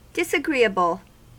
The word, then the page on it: disagreeable: American English